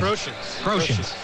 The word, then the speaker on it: Groshans: Jordan Groshans? How do you say his name? – Groshans. Yeah, he is coming.